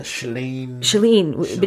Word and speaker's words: Shailene: Shailene Woodley, I think is how you pronounce her name.